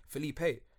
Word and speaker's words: Felipe: Like here, everyone calls me Felipe, but in Colombia, it's pronounced Felipe. Do you get what I'm saying? There's a massive difference, but obviously, not everyone's gonna say Felipe. So you just say, "Oh, bun it, just call me Felipe."